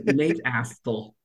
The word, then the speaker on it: Nate Astle: No, it's Nate Astle.